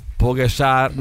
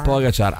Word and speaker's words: Pogačar: Pogačar, non si dice così. – Pogačar ha vinto il Tour de France.